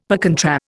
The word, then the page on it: per contra: Indian